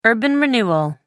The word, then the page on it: urban renewal: American English